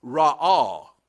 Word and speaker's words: ra'ah: And the third Hebrew word is very similar in pronunciation to re'ah. It's called ra'ah. Ra'ah. One is re, the other is ra. This particular Hebrew word is spelled R-A-A-H, R-A-A-H, ra'ah.